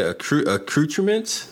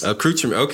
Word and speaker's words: accoutrements: How do you say accoutrements? – Accoutrements? – Accoutrements. Okay, I got it right.